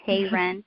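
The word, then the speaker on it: Heyrend: My name is Christina Heyron. I'm the owner of Christina Heyron Photography. Christina spelled with a C-H, and then last name, Hey, and then Rend as in tear, Hey Rend.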